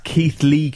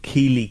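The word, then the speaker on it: Keighley: It's pronounced Keith Lee Cougars, not Keely Cougars.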